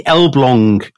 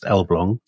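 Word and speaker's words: Elbląg: This is the Elblong Canal. It's spelt Elblag, but it's pronounced Elblong. So my Polish contacts tell me.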